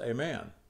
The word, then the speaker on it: aman: And in the Hebrew, the word is aman, and it's pronounced aman.